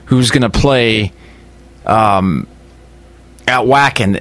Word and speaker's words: Wacken: It's pronounced Wacken. – Wacken, right. Because it's German.